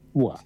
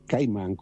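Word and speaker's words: Bois Caïman: Bois, i francesi scrive Bologna, o tanto vimo la Savona, Bois, sì, sì. – E Caiman, per gli italiani è Bois, cioè Bologna, Washington, Ancona, e Caiman con la K, Caiman.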